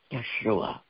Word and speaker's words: Yeshua: My Texas accent isn't quite the accurate. It should be Yeshua.